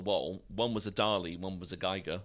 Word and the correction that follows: Giger: or a Giger, I should say, to pronounce it correctly.